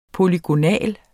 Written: polygonal

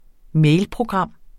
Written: mailprogram

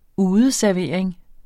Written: udeservering

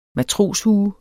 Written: matroshue

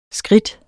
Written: skridt

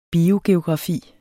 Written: biogeografi

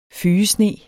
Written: fygesne

